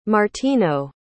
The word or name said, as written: martino